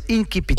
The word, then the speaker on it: incipit: Ou si on le prononce comme en latin, c'est incipit.